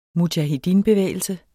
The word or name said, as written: mujahedinbevægelse